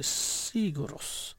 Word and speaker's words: Sigur Rós: Det er også Sigur Rós. Det er jo ikke, det er jo ikke Sigur Rós. Det er Sigur Rós.